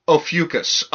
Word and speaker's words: Ophiuchus: Officius? – Oh, yeah. No, I looked up... – Officius? – Ophiuchus. Okay, yeah, I remember because it's not pronounced like it's spelled. It's actually pronounced Ophiuchus.